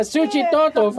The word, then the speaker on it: Suchitoto: So yeah, Suchitoto, for all those of you got like the giggities in your heads. It's located northeast of the capital city. And San Salvador, Suchitoto is a former colonial city.